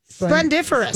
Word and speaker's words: splendiferous: Or splendoriferous. – Yeah. – That's another one. – I don't know that you said it right. – Of course I didn't. Splendiferous.